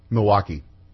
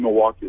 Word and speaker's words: Milwaukee: Milwaukee. – Yeah, no, except if you're from Milwaukee, it's called Milwaukee. It's two syllables.